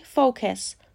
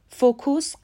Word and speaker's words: focus: تلفظ صحیح فوکس فوکوس غلطه یک اصطلاحی که در حوزه تبلیغات زیاد استفاده میشه فوکس گروپ هست.